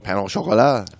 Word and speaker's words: pain au chocolat: I would like some, what is it, pain au chocolat? Am I saying it right? – Oui, pain au chocolat.